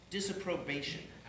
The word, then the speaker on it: disapprobation: And the first one, disappropriation? – It's a disapprobation. Thank you.